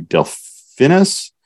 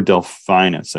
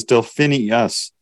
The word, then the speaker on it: Delphinus: Yeah, so if we continue to pan down towards the southeast, we'll eventually get to Delphinus or Delphinus. I've heard it pronounced a lot of different ways. I've even heard it pronounced as Delphinius. There's not another I in there.